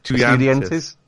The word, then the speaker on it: Estudiantes: They then moved to Boca Juniors, Sampdoria, Parma, Lazio, Manchester United, Chelsea, Inter on loan, returned to this club that I can't pronounce. – Have a go. – Estudiantes? Yes.